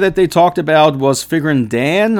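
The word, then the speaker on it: Figrin D'an: I know you guys have been pointing out multiple times now that I am mispronouncing the name. But, you know, to me, there is an apostrophe between the D and the A, and I've just always called the character Figurine Deanne and not Figurine Dan.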